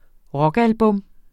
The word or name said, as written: rockalbum